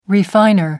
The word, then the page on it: refiner: American English